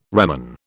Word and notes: reman: British English

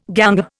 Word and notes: ganger: Indian

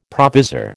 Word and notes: provisor: American English

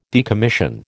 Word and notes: decommission: American English

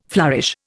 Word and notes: flourish: Indian

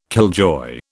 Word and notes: killjoy: British English

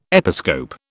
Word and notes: episcope: British English

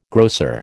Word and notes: grocer: American English

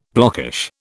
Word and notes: blockish: British English

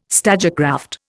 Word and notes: stagecraft: Indian

